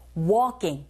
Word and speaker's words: walking: 주의하실 건 L이 묵음이라는 거. Walking. Walking. 크 발음이요. 살짝 된 소리가 나서 walking 보다는 walking. Walking 하시면 됩니다.